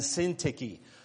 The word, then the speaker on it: Syntyche: The best pronunciation I've come up with is Euodia and Syntyche.